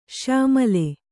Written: ಶ್ಯಾಮಳ

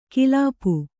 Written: ಕಿಲಾಪು

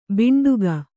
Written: ಭಾಂಡಿಕ

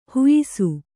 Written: ಹುಯ್ಸು

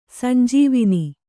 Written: ಸಂಜೀವನೆ

